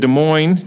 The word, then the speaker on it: Des Moines: And then also in Des Moines, you don't pronounce the S, Brett, I believe we call him Give Me a Meal Healy with 32.5.